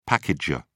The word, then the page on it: packager: British English